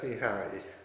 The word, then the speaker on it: Mihaly: Mihai, is that correct pronounced? Mihai, yes.